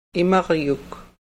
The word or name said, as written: imaryuk